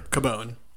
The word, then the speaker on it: Cubone: Cubone.